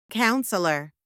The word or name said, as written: counsellor